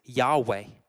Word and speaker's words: Yahweh: And the way we pronounce it these days is Yahweh.